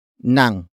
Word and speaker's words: ng: The next word is nang, which is spelled as ng.